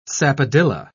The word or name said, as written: sapodilla